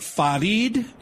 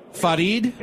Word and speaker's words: Farid: Is it Farid? Did I say your name right? Farid? – Yeah, hello, Mr. Gino.